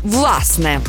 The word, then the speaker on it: власне: Наголос на першому складі «власне».